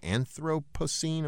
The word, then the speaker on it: Anthropocene: Some people now refer to the geological age we live in now as the Anthropocene. Am I pronouncing that right? – Yes, I believe so.